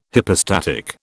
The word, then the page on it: hypostatic: British English